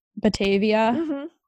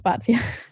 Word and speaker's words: Batavia: It's a Midwestern thing. It looks like Batavia, like Germany. – Yeah, that's what I was like. – No, but it's Batavia.